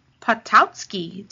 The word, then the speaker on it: Potosky: This is Tammy Potosky.